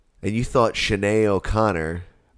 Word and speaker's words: O'Connor: Sinead O'Connor. That's how you say it.